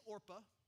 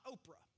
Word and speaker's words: Orpah: You think I'm saying it wrong, but it is Orpah. It's not Oprah.